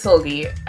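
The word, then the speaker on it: Seulgi: I feel like I've been saying her name wrong for years. – No, I think it's Solgi. Right? Yes. – When I heard that guy Nathan say it, it sounded really different. And I was like, I don't know how to pronounce that. – No, it's Solgi. I think we're good.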